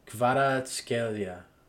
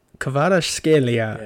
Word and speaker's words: Kvaratskhelia: Kvaratskhelia. – Kvaratskhelia.